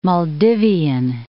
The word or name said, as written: Maldivian